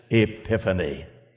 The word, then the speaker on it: epiphany: We spell that out in English exactly as it is in Greek, except we pronounce it epiphany.